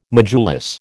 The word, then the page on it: modulus: American English